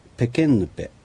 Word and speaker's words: ペケンヌペ: 次にペケンヌペ。 – ペケンヌペ、清い涙。 – はい、ペケレ、清いとヌペ、涙、これを続けて発音するとこのような言い方になります。